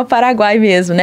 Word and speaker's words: Paraguai: O Paraguai chama Paraguai mesmo, né? Mas Paraguai. Esse U, então, por exemplo, Curuguatu, né? – Não cabe na palavra Paraguai a mesma conotação? – Não, não. Não, porque o Paraguai já se chama, não é Paraguaú, né? – Uh-huh.